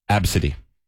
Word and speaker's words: ABCDE: They pronounced the kid's name as Absidy.